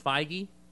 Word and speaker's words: Feige: He and Kevin, I've heard his name pronounced Feige. So that's what I'm going to go with. He and Kevin, I'd say Fergie.